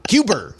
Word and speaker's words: Cuba: Cuber. That's how we pronounce it in Boston.